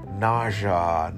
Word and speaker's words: nausea: in portuguese we say nausea and because you write N A U S E A. But in uh, you, you write the same as in Portuguese, but you pronounce naja, naja, ja, okay? S E A, okay, is the end of the word, but you say j, j, okay?